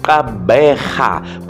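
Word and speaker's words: Gqeberha: I the pronunciation of Port Elizabeth, I said Kabeja, but now people just corrected me. I said I cannot pronounce it properly, and people say no, it's Kabeja, ne? Kabeja, I think so, man. Ah, man, this new name, yeah, it's a bit difficult to pronunciate, man. But there's people that's getting it right. Kabeja, Kabeja, Kabeja, not but Kabeja.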